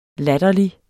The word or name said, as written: latterlig